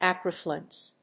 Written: acriflavin

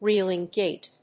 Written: reeling gait

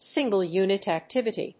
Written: single unit activity

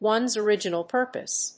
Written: ones original purpose

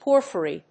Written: porphyry

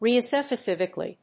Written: regiospecifically

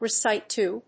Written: recite to

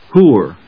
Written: whore

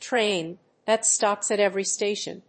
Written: train that stops at every station

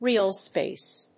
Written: realspace

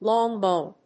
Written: long bone

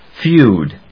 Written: feud

